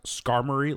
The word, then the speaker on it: Skarmory: If Pokemon were real, I found out last night that it's pronounced Skarmory, like an armory.